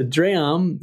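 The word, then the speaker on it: drēam: Yeah, so dram looks like the word dream, but it's pronounced a little bit differently, and it's etymologically linked to the word dream we have today.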